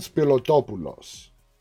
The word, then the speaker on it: Spilotopoulos: I see, for me to say it, I got to say it Greek. I got to say it in Greek. Because when you try to say a Greek name in English, it just comes out all butchered. So it's Evan Spilotopoulos. You see? If I say it in Greek like that, it comes out perfect.